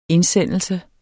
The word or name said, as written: indsendelse